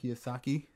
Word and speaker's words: Kiyosaki: I want to buy uh, Rich Dad Poor Dad by Robert Kiyosaki. Kiyosaki.